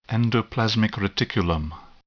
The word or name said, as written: endoplasmic reticulum